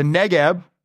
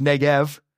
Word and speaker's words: Negeb: The Negeb, sometimes pronounced Negev, is a desert in the southern part of Israel.